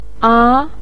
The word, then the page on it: a: a2